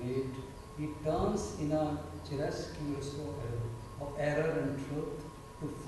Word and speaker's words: chiaroscuro: Chiaroscuro.